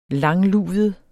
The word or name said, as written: langluvet